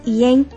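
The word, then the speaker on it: yen: イを発音して、うん、イエンって言うんですけれども、はい、ここは複数形になっているのでイエネスになります。 – あ、なるほど。じゃあ、ウンイエンだったらウンイエン。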